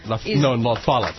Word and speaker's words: La Follette: La Follette. He pronounces it on the first syllable. – La Follette. – In French, it's La Follette. – You put the emphasis on the wrong syllable.